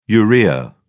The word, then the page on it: urea: British English